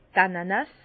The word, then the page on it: d' Ananas: Bas Rhin; Herrlisheim